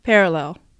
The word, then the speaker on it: parallel: pair と lel に区切って発音します pair lel pair lel といった感じです少しずつ間を短くして最終的には parallel と発音しましょう。